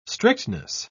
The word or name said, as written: strictness